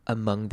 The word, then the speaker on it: among: Rooted is deeply rooted among their beliefs. Among 这个字要注意的是，虽然它是 ong，但是这个 o 一念的声音。